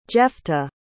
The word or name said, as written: jephtah